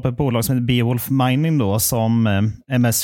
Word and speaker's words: Beowulf: Ja, jag brukar säga Beowulf Mining.